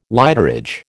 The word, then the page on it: lighterage: American English